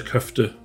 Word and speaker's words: Köfte: Sein Unternehmen Itikal Helal beliefert seit vielen Jahren inzwischen mehr als 4600 Lebensmittelgeschäfte in Europa mit Tiefkühlprodukten, wie beispielsweise Köfte oder Börek. Ich hoffe, das habe ich jetzt richtig ausgesprochen, Köfte und Börek, Herr Erkurt. – Ja, das haben Sie.